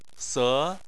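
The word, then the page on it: se: tone 2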